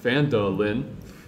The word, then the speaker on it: Phandalin: Times were good, and the nearby human town of Phandalin, pronounced Phandalin, prospered as well.